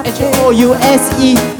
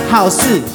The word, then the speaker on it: house: 你不会把 house H O U S E，你不会把它念成好事，好事多多，不会把它念成好事，因为它叫做 house。